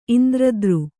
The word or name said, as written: ಇಂದ್ರದ್ರು